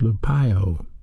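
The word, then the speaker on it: lupeo (λυπέω): The original word for grieve is pronounced lupeo, L-U-P-E-O, and it's a verb.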